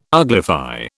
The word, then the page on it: uglify: British English